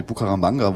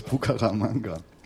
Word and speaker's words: Bucaramanga: Das kommt an aus Bucaramanga. – Hast du richtig ausgesprochen? – Danke. – Fast.